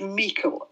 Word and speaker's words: Meikle: but George Meikle is probably the correct pronunciation. It's a Scottish surname.